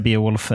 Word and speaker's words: Beowulf: Ja, jag brukar säga Beowulf Mining.